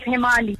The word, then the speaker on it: Hemali: It's Hemali.